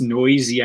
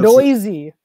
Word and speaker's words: Neuse: Actually, it's pronounced Noisy. I know, really unusual. – Noisy.